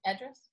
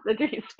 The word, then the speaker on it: Idris: Idris, right? – Idris? Yes, Idris. Idris with my address.